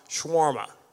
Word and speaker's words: shawarma: Shawarma.